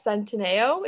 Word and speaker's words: Centineo: So, rumors are going around that Noah Centineo, is that how you say his name? Yes.